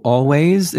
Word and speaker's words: Alvvays: Oh, Always? It's spelled like A-L-V-V-A-Y-S.